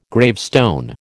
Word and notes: gravestone: American English

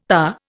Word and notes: tahr: Indian

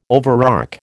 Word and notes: overarch: American English

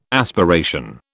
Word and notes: aspiration: British English

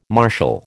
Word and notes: marshal: American English

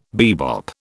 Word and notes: bebop: British English

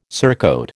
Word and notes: surcoat: American English

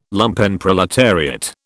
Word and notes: Lumpenproletariat: British English

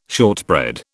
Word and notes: shortbread: British English

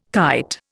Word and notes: kite: Indian

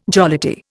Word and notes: jollity: Indian

